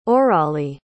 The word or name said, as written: orali